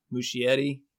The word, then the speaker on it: Muschietti: And Andy, how do you say his last name? Muschietti? Yeah, Muschietti.